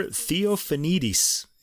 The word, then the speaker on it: Theofanidis: Christopher Theofanidis. – Yeah, Theofanidis.